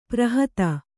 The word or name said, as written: ಪೌರೋಹಿತ